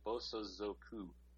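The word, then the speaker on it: Bosa Zoku (暴走族): Now, by the way, I've been saying it wrong. It's Bosa Zoku.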